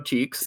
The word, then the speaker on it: Cheeques: I don't know if people picked up on this, but when he goes into Cheeks, that's the name of the strip club, Cheeks. Sick. It's spelled C-H-E-E-Q-U-E-S. – Love it. – But it's called Cheeks.